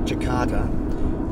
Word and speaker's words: Jakarta: But we call it Jakarta because we've still can pronounce the T's because we're part of the Commonwealth.